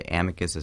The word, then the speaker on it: Amicus: We pronounce Amicus.